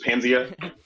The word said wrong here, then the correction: Paznia: Yep, it's Paznia.